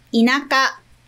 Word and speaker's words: inaka: E esses dois kanjis juntos, né, tem pronúncia de inaka. Então, não tem esse negócio de primeiro kanji tem pronúncia de i e segundo é naka. Não, os dois juntos é inaka.